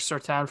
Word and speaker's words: Surtain: But the way I see it, they take Patrick Surtain. Is that how you say his name? – Patrick Surtain from Alabama.